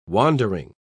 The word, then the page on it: wandering: British English